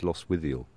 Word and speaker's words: Lostwithiel: Is it Lostwithiel? – I would say it's Lostwithiel.